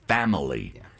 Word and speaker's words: Phamaly: Oh, I should say that Family is not spelled the traditional way. It's spelled PHAMALY, Family.